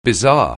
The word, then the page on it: bizarre: anglais